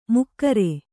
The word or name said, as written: ಮಕರಿ